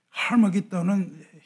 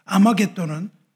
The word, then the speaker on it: 아마겟돈: 여러분이 인류 최후의 전쟁이라고 알려진 아마겟돈은 원 발음이 하마기돈입니다. 하마기돈은 히브리어고 헬라어로 음역을 하면 아마겟돈이에요. 하라는 것은 뭐냐면 산이라는 뜻입니다. 하마기돈은 뭐냐면 무기도의 산이라는 뜻이에요. 예루살렘은 아, 이 아마겟돈은 무기도입니다.